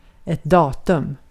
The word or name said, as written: datum